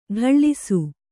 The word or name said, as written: ಠಾಳಿಸು